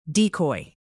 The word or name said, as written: decoy